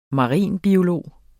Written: marinbiolog

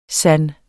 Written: sand

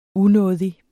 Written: unådig

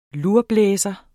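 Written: LURBLÆSERE

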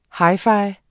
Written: hi-fi

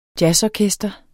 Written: jazzorkester

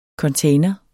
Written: container

